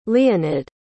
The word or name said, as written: leonid